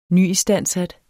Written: nyistandsat

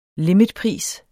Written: limitpris

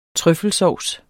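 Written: TRØFFELSAUCE

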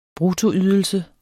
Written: bruttoydelse

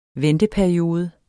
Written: venteperiode

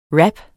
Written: wrap

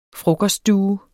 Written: frokoststue

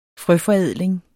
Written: frøforædling

